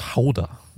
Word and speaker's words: Gouda: I say, apparently it's pronounced Hauda. Like Hauda. That's how you say it.